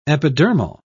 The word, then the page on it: epidermal: British English